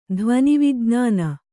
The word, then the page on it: ಧ್ವನಿವಿಜ್ಞಾನ: dhvanivijñāna